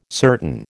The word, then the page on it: certain: American English